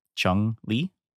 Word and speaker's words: Chun-Li: Chun-Li.